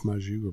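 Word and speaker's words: maque choux: Looks like it's spelled mock chow. It's pronounced maju or mashu.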